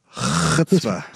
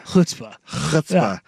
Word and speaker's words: chutzpah: I believe it's pronounced chutzpah. – Chutzpah. – But you got to get a good, throat thing in there. Chutzpah.